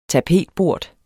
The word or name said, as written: tapetbort